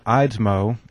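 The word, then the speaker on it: Eidsmoe: Each class will feature an informative 30-minute video lecture by constitutional attorney and professor Dr. John Eidsmo. Did I pronounce that correctly? – Yes, you did.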